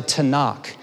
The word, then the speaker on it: Tanakh: Altogether, the Torah, the Nevi'im, and the Ketuvim, it creates this acronym, if you will, that's pronounced the Tanakh.